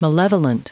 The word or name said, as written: malevolent